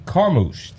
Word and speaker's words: Carmouche: Mr. Ken Carmouche.